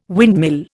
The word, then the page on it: windmill: Indian